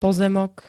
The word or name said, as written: pozemok